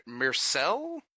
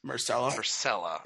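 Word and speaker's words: Myrcella: Myrcelle? What's her name? – Marcella.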